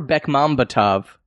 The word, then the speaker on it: Bekmambetov: Berkembetkov. – That's Berkem not how you Betkov. Say his name. – Yeah, it is. – It's Timur Bekmambetov.